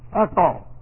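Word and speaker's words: Echo (ἔχω): E-C-H long O. Echo.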